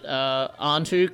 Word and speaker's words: Anhtu: Yeah, just follow me on Twitter at Antu Comedy. That's spelled A-N-H-T-U and the word comedy.